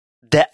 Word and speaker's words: det: De Asom я hörde en song. De – это de. Обращая внимание на то, что буковка t в этом местоимении не читается.